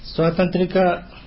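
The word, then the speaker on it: Svatantrika: I'm sorry, it's quite a specialized question about the use of the logical argument between Satantrika and Prasangika methods. So, what is the difference in the way both use the logical argument? – Usually it is said like this. Swatantrika Madhyamika philosophy, Swatantrika Madhyamika philosophy uses logic, you know, like a really logic way.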